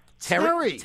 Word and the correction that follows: Turi: Terry. Hi, Terry. – It's Turi. – Oh, Turi. What, how do you spell that? – T-U-R-I. – T-U-R-I.